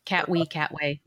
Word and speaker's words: Katwe: Katwe. I think I'm saying that right. – I think so. Catway. Yeah, sounds good.